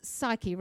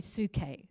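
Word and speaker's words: psuche (ψυχή): The soul is, it says psyche, right? But it's actually pronounced, if you were Greek, you would say psuche. Psuche.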